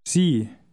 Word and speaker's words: Sie: Initial S is usually pronounced like a Z. Now read 4. – Sie.